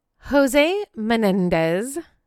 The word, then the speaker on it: Jose Menendez: Jose Menendez.